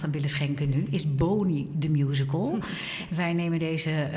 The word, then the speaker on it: Boni: Ja, Boni moet je het uitspreken. – Boni. Bonnie. Goed, Bonnie.